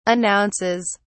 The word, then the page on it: announces: British English